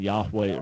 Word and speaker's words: Yahweh: Jewish scholars would basically agree that the original pronunciation would have been Yahweh or something close to that.